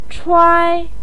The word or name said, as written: chuai1